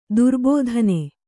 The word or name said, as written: ದುರ್ಬೋಧನೆ